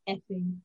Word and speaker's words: Fing: While the French aristocracy are losing their heads, quite literally, two bored English noblemen, Sir Frederick Rodney Fing, pronounced Effing,